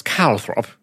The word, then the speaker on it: Calthrop: Charles Calthrop. – Calthrop, like Caltrop. – Sort of, but it's spelled like Calthrop. Calthrop.